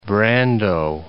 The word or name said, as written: Brando